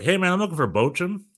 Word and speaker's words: Bochum: VfL Bochum, I believe is how you pronounce it. Right? – Yeah, very nice.